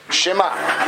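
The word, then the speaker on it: Shema (שמע): So the accurate pronunciation of the word Shema is not Shema, it's not Shema, but rather Shema. Meaning you pronounce, you hear an is sound, but it connects to the next letter.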